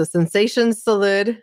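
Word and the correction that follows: Sensación: so that people can get it correct. I want to say Sensacion. – Yes. Yeah.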